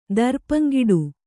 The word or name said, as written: ದರ್ಪಂಗಿಡು